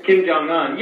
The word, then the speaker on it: Kim Jong-un: Much like Kim Jong-un, I don't even know how to say his name, you know? – Yeah. Kim Jong-un, my father calls him Kim Ping Pong, but it's Kim Jong-un.